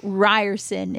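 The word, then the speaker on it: Riorson: So Ryerson is how you say his last name.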